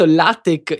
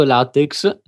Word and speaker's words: LaTeX: Allora, pronunciato LaTeX in italiano, errato LaTeX.